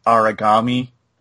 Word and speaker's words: Aragami: There is a game called, I want to say it's pronounced Aragami. It's spelled A-R-A-G-A-M-I.